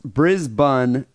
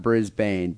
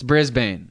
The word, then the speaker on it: Brisbane: It's pronounced Brisbane, not Brisbane, but that's a common mistake." – Well, yeah, but we're from America. We don't pronounce anything the same as you guys. – It's Brisbane.